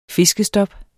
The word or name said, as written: fiskestop